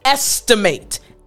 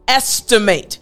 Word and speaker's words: estimate: Now, I do want to break down the pronunciation of this word estimate. Estimate. Now, this word is something that we use on a regular basis in English. So, in order for you to pronounce this word properly, estimate, estimate, I want to focus on the ST portion.